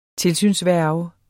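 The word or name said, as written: TILSYNSVÆRGEN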